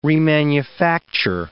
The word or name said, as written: remanufacture